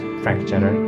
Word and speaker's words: Frank Jenner: I don't know why he pronounces that way, but it's Frank Jenner.